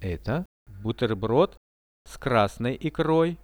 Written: eto buterbrod s krasnoi ikroi

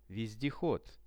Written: vezdehod

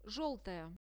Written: zheltaya

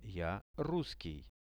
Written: ja russkij

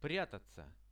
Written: priatatsia

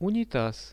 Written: unitaz

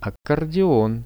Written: accordeon

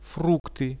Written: frukty

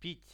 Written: pit

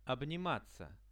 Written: obnimatsia

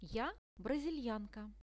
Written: ja brazilianka

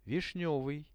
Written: vishnevyj

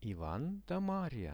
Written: ivan-da-maria

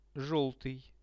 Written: zhelty

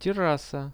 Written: terassa

